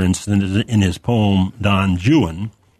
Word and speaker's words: Juan: And so it's spelled J-U-A-N and pronounced Juan, of course, but Byron makes you say Juan.